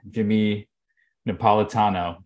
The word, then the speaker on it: Jimmy Napolitano: We got our friend, our emerged brethren, Jimmy Napolitano.